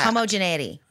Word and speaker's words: homogeneity: Homogeneity. – Yeah. – That's how you say it.